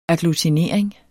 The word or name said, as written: agglutinering